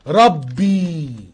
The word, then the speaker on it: Rabbi: دي كلمة عبرية، كلمة عبرية نطقها كده ربي، R A B B I أو رو ألفا بيتا بيتا إيوتا.